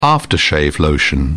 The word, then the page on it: aftershave: British English